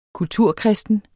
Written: kulturkristen

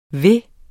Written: ville

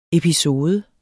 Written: episode

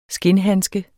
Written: skindhandske

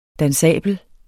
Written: dansabel

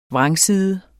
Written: vrangside

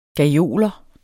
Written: gajoler

